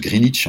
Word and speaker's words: Greenwich: Alors on prononce Greenwich en anglais.